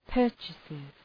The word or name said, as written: purchases